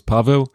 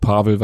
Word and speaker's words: Pavel: Ich heiße also auf Polnisch heißt es Paweł, aber ich sage auch Pavel, weil ich rede ja kein Polnisch.